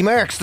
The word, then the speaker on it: Merckx: It is the birthday of, and I'm going to get his name wrong, but you can correct me, Eddie Merckx. – Eddie Merckx, the cyclist, yeah.